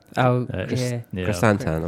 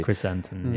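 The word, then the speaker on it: Krrsantan: So you've also got, I'm not sure how you pronounce his name properly, Chrysantan? – Chrysantan. – I'm sure that's how you... – Chrysantan, yeah.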